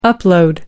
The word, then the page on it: upload: American English